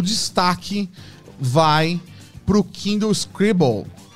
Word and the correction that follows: Scribe: Kindle Scribe, não sei a pronúncia. – Scribble, que é... – Soletra pra ajudar. – É verdade, se escreve S-C-R-I-B-E.